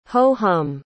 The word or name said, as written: ho-hum